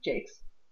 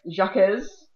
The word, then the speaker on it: Jacques: So Brian Jakes, pronounced Jakes, it looks like Jacques, or Jacques,